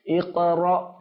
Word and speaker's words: اقرأ: اقرأ اقرأ إذا حمزة হামজার উচ্চারণ যখন সাকিন বিশিষ্ট হয় তখন একটু ধাক্কা দিয়ে উচ্চারণ করতে হয়। আমরা কোথায় পড়েছিলাম বলুন তো? মাদিনী কায়দার মধ্যে, মনে আছে আপনাদের? মাশাআল্লাহ। তাহলে উচ্চারণ কি হবে? – ইকরা, একটু ধাক্কা দিয়ে, ইকরা বিসমি রাব্বিকাল্লাযী খালাক।